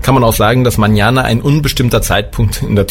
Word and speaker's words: mañana: Erstmal eine Sonderheit, Sie kennen das alle, das N mit diesem Schleier drauf, das wird ausgesprochen wie ein NJ. Ja, Sie können also als Beispiel España, kennt jeder, oder aber auch mañana, heißt morgen.